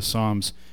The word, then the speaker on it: psalm: Now, Psalm, the word psalm, and yes, one of the strange evolution of the English language, P is silent, okay? It's not psalm, it's psalm. The P is silent.